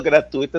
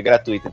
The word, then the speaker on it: gratuito: Quando eu falo gratuito, as pessoas me olham às vezes só estão me olhando para prestar atenção no que eu estou falando. Eu falo, ih, já falei errado, é gratuito, então.